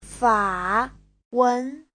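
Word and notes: fawen: fa3wen2

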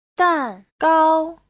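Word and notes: dangao: dan4gao1

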